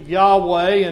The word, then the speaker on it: Yahweh: And they would pronounce it Yahweh in the Hebrew language.